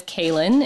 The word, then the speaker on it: Cailin: My given name is Kaylin. It's the Gaelic spelling of Colleen, so it's spelled C-A-I-L-I-N. The more common form of it on this side of the pond is Caitlin with the T, Caitlin or Kayla, and it tends to be spelled with a K.